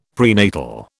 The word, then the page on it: prenatal: British English